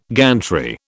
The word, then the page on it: gantry: British English